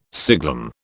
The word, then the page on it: siglum: British English